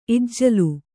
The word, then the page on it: ಇಜ್ಜಲ: Kannada